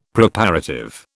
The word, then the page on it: Preparative: British English